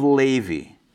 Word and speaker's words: Levi: Above the Israelites, you have the tribe of Levi, or Levi. I'm going to say Levi, because that's a Hebrew way of pronouncing it.